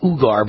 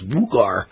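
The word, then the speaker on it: Uyghur: I've been calling it Ugar, but one of my fans evidently says it's not called Ugar. I'm not pronouncing that form of or style or type of Muslim correctly. It's Wugar. It's spelled Ugar, but it must be Wugar.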